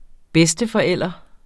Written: bedsteforælder

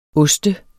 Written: oste

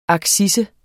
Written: accise

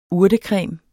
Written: urtecreme

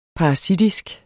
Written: parasitisk

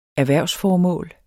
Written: erhvervsformål

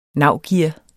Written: NAVGEAR

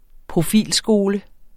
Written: profilskole

